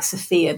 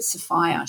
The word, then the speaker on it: Sophia: It's like Sophia, but they pronounced it Sophia.